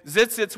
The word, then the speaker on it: tzitzits: These tassels were called tzitzits. Yes, that's actually the pronunciation for them. And tzitzits were a display of importance a family lineage, of your prestige.